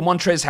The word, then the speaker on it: Montrezl: The table, Montrezl Harrell, because his name, Montrez, with that silent L, it really should be Montrezl.